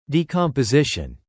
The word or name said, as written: decomposition